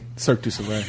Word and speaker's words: Cirque du Soleil: Circus de Soleil. – Cirque du Soleil. – Cirque du Soleil. Oh, I love that.